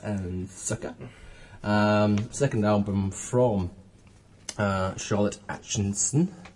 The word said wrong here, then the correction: Aitchison: Atchison. – Aitchison. Aitchison, yeah.